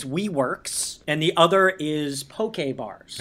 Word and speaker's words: poke: P-O-K-E, poke. Not pronounced poke, poke.